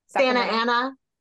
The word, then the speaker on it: Santa Ana: Santa Ana. We don't pronounce the T's.